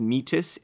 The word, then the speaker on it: metis: We're pretty sure it's pronounced metis, M-E-T-I-S. But metis is this idea of local knowledge that comes from interacting with the system.